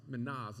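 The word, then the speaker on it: mina: Calling 10 of his servants, he gave them 10 minas. Minas is the way that's pronounced.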